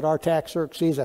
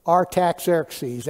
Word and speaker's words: Artaxerxes: Okay, the reason I kind of paused at Artaxerxes, I found out this week I'm pronouncing that name like a Texan. That's not really the way it ought to be pronounced, but that's the way you're going to get it from me. Artaxerxes, that's just the way it comes out of my mouth.